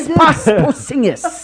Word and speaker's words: Kristaps Porzingis: Kristaps Porzingis.